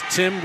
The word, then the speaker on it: Tym: And Richardson, pronounced Tim, spelled T-Y-M at the line, shooting one.